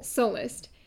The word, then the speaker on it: Sullust: Sullust. – Emphasis on the first syllable. – Sullust.